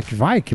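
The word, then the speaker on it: Kveik: Kvike, como se pronuncia isso? É Kvike é mesmo? É Kvike. Segundo, é. segundo ele falou no programa, é Kvike. Esse é o jeito errado que a gente fala.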